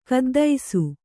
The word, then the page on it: ಖಿದ್ದಿಸೆ: kannada